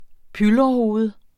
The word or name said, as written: pylrehoved